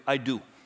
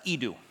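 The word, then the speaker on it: edu (ἰδού): And the word here for behold, in English, it's transliterated to I do. I do. It's actually pronounced edu. It just means see, look at that. Behold, check this out, is the idea. Edu, pronounced edu. Looks like I do.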